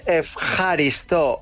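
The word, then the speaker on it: efjaristo (ευχαριστώ): Se dice efjaristo.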